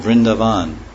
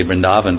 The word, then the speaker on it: Vrindavan: Yeah, it's like not, the A is hardly long, it's not like it is. Now people say Vrindavan. It's like Vrindavan. Yeah, it's just a hardly, it's very small, short, long A.